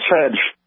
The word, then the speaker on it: Fej: The name I hear has F-E-J. Is that Fedge? – That is Fedge.